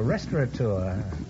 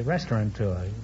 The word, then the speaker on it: restaurateur: Oh, a restaurateur. Many people mispronounce it and say restaurateur.